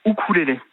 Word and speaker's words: ukulélé: Alors normalement on dit ukulélé. Le U en océanien se prononce ou, bon. Donc normalement on devrait dire ukulélé, c'est la prononciation.